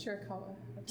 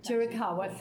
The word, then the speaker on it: Chiricahua: and a descendant of the Chiricahua Apache. Am I correct? – Yes, Chiricahua. – Chiricahua, thank you for correcting me on that.